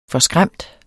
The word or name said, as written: forskræmt